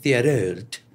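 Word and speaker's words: Thériault: Pues a pesar de que hay pocas maneras de entender el reino del terror de Roch, Theriault, no sé cómo se pronuncia, es francés, Theriault. – Theriault. – Es importante, si se escribe T-H-E con acento al revés, Riault.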